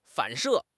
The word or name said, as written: 反射